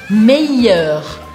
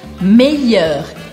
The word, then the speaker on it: meilleur: Meyer. Meyer. Eu quero que você esqueça que tem um L aqui no meio e que você pronuncie como se fosse um I.